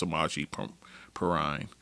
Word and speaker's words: Samaje Perine: Uh, Samaje Perine uh, he's another one I mispronounced his name uh, in the last podcast, guys. It's Samaje Perine uh, for the Bengals.